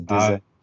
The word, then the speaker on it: DZA: La, prononciation, c'est comme Reza, tu vois, c'est DZA. Ah, DZA, ouais, comme César, comme.